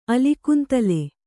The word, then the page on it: ಅಲಿಕುಂತಲೆ: alikuntale